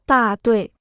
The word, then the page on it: 大队: dà duì